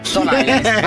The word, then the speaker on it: Stone Island: Questi jeans della Stone Islands. – Stone Island.